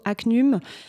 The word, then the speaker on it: HACNUM: Donc, le jeudi après-midi à 14h, on reçoit le réseau ACNUM. Ça s'écrit H-A-C-N-U-M.